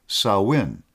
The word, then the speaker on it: Samhain: Spelled S-A-M-H-I-N, it is pronounced S-O-W-W-I-N, Samhain.